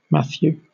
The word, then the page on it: Matthew: /ˈmæθjuː/